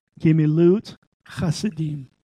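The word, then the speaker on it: Gemilut chasadim (גמילות חסדים): The second word is O-R-C-H-I-M, or him. Yeah. It's G apostrophe M-I-L-U-T. Second word is chasadim, D-I-M. Gemilut chasadim.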